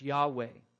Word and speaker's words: Yahweh: And we best understand how to pronounce it, I believe, as Yahweh.